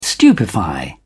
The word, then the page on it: stupefy: British English